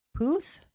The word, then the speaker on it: Puth: I got Uptown Funk, Bruno Mars, Charlie Puth. Is that how you say his name? – Yep.